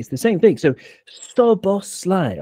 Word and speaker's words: Szoboszlai: I've got to pick you up on the pronunciation, though. I've heard this so many times. It's Szoboszlai or Szoboszlai. – Yeah, I don't know. – No, I know. It's very easy because SZ in Hungarian is just S.